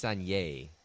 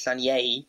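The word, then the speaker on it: Sanllehí: I still can't pronounce his name. Sanlehi? – I think it's Sanye. – In Spanish, Sanye. – Yes. – Expertly pronounced right there.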